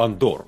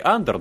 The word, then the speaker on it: Андор: Я буду называть его Андор. Он в оригинале Андор, но мне кажется, здесь э, какая-то русификация, наверное, предполагает все-таки перенос ударения на второй слог. Как-то более правильным, кажется, такое звучание.